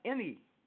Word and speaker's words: Emme: My website is Emme, pronounced Emme. – Mm-hmm. – It's not with a Y, it's Emme. With a Y, E. Emme, Emme Kemp. Kemp. It's not Kim, and they look for that too. Kemp. Emme Kemp. All, A-L-L, music, M-U-S-I-C, .com.